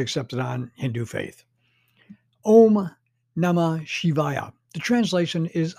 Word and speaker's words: Om: Om, pronounced Om, the primal cry, it means it is, will be, or to become.